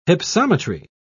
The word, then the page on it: hypsometry: British English